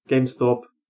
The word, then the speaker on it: GameStop: ich glaube tatsächlich, dass GameStop, jetzt habe ich es richtig gesagt, Die Zeit, die Hochzeit ist, wenn man es als Hochzeit bezeichnen kann, die ist rum.